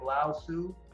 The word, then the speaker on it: Lao Tzu: Jack Daly is amazing, and that is a very old one from Lao Tzu, L A mm-hmm. am I saying it right? Lao Tzu? I, am pretty sure, yeah.